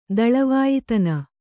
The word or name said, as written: ದಳವಾಯ್ತನ